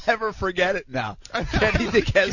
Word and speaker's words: Duquesne: From the guy who couldn't pronounce, and I still can't pronounce the Duquesne or whatever university. – Duquesne.